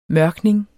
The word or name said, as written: mørkning